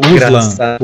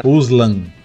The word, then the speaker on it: Uslan: Mike, não é, Michael Uslan. Uslan.